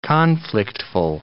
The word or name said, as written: conflictful